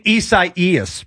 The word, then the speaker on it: Isaias: Hurricane Isaias, by the way.